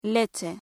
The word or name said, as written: leche